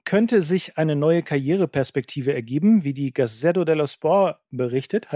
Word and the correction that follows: Gazzetta dello Sport: Habe ich das gut ausgesprochen? Gazzetta dello Sport, so ein bisschen Jovial dahin.